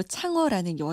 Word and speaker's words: 창어: 우리 발음으로 항아, 중국 이름으로 창어라는 여인이 있었는데요.